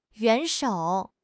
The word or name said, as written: 元首